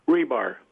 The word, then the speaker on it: Rebar: Frank, how do you pronounce your name? – Rebar. – Rebar? – Yeah. – You mean like the stuff they put in nuclear plants? – In concrete.